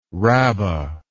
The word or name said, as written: rabbah